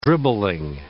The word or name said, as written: dribbling